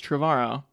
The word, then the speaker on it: Trevorrow: You should read the recent Uproxx interview where he says, everybody says my name wrong, it rhymes with tomorrow, it's Trevorrow.